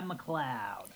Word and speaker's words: McLeod: Yeah, I'm um, here to talk about Homicide McLeod. – That's how it's pronounced.